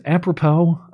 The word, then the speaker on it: apropos: How do you say this? Apropos? That's a word I never use in everyday discussion. – That is correct.